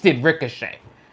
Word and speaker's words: Ricochet: You done wasted Rico. – It's three syllables. – Wasted Ricochet, and completely thrown Aleister Black in the garbage.